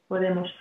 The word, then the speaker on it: Podemos: Podemos.